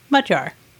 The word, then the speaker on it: Magyar: First of all, that G-Y in the center is actually a letter in the Hungarian language. And it has a sound that is similar to the DG in the word edge. I like to teach the pronunciation of Magyar to people using two words we are familiar with saying in English, mud and jar.